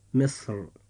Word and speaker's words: مصر: ففي هذه الحالة يجوز تفخيم حرف الراء ويجوز ترقيقه هكذا. – فتنطق هذه الكلمة في حالة التفخيم مصر،